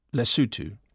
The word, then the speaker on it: Lesotho: And Lesotho, 100% inside South Africa, pronounced Lesotho.